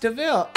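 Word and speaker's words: de Vil: Cruella Devil. – Nice, man. It's pronounced Deville.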